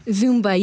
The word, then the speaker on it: Zumba: is it pronounced Zumba? – Zumba, yes.